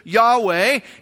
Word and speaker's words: Yahweh: We pronounce it in English as Yahweh.